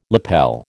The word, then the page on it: lapel: American English